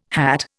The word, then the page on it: hat: Indian